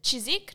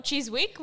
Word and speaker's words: Chiswick: We went to Chiswick, not Chiswick, we have learnt. – But it is spelt with a W.